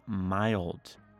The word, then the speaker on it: mild: And then next one, mild. Makes a long I sound. Mild. Kind of like smile with a D.